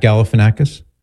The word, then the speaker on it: Galifianakis: The actor Zach, I always trip over his name, Galifianakis. Is that how you say it? – Nice work. – Zach Galifianakis.